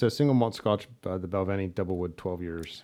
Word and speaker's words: Balvenie: The Valvine. – Balvenie? – The Balvenie. – Balvenie.